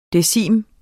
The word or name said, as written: decim